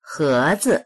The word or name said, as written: he2zi